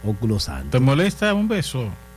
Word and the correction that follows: ósculo: Sí, pero no le ponga el acento en la U. – Saludarlo con óculo santo. No le ponga el acento en la U. – Ósculo santo.